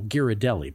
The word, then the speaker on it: Ghirardelli: Excellent pronunciation on Ghirardelli, by the way, as well. The San Francisco pronunciation of Ghirardelli.